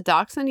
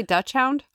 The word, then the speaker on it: dachshund: That's a dachshund? You don't say Dutch hound? – No. Just you.